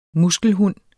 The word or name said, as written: muskelhund